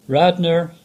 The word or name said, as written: Radnor